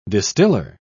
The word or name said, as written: distiller